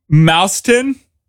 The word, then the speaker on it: Mauston: Because Sandra, she's from Mauston. – Boston. – Okay, like Boston. Like rhymes with Boston.